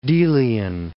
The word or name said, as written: Delian